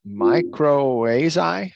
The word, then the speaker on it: mycorrhiza: It was mycorrhizae.